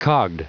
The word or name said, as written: cogged